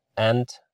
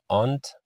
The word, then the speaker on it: aunt: aunt یا aunt دو تا تلفظ داره که میشه عمه یا خاله.